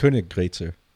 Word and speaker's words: Königgrätzer: Is it the Königgrätzer March? – Königgrätzer. You're close. – What is it? – Yeah. They just did those umlauts, change the pronunciation. – Yeah, how do you pronounce it? – Königgrätzer.